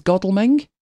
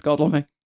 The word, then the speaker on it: Godalming: I think there's Godalming, which I think that's spelt the way you would say it. But she called it Godalming, which is, no, it's Godalming. We've got one near us.